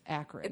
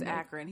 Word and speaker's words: Akron: Yeah, Akron. He said it wrong. And I was like, yeah, he even said, if you're not from Ohio, you know it's Akron. – It's Akron.